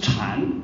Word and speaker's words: 禅: 本来禅在中国的发音是禅，不是 Zen，